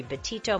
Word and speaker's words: Betito: You can also connect with me on social media at Dr. Laurie Betito. My last name is spelled B-E-T-I-T-O.